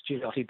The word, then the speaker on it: Gigliotti: It's pronounced Giuliotti, but it looks like it says Gigliotti.